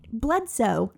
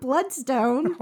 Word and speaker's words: Bledsoe: Tempest Bledstone. – Bledsoe. Not Bloodstone.